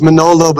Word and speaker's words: Manolo: My name is Manolo, but people can't say Manolo. They want to say Manolo, like the singer Barry Manilow. – So. Well, that's it spelled with an A, right? – Yeah, it's M-A-N-O-L-O, which...